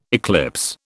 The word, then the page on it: eclipse: British English